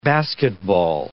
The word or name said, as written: basketball